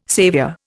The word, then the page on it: saviour: Indian